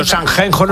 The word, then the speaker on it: Sanxenxo: Digo, de Ciudad Real, es que ahí eso se pronuncia así, Sanxenxo.